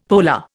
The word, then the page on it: polar: Indian